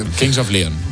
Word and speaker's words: Kings of Leon: King of Leon, of Lion, no sé cómo se dice, la verdad, porque... – Kings of Leon.